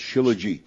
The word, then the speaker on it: Shilajit: Okay, it's spelled S-H-I-L-A-J-I-T. – All right, and it's pronounced Shilajit.